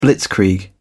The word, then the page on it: blitzkrieg: British English